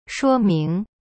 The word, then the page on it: 说明: shuo ming